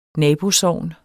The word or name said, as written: nabosogn